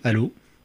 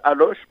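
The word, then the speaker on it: Allauch: Comment ça s'écrit Allô alors souvent les Parisiens appellent ça Aloche parce que ça s'écrit A2L A U C H.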